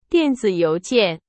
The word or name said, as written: 电子邮件